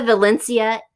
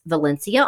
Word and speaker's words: Valencia: Lisa Valencia is here. And yes, you do pronounce it like you pronounce Valencia oranges.